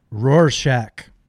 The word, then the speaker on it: Rorschach: If you see the neon tiger and the the um, the Rosach, Ros, what, how do you say it? Rorschach.